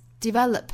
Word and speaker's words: develop: Set us free, 或者是 set us free, until we develop the skills, develop, 单词尾部的这个 p 的发音我们做失去爆破处理。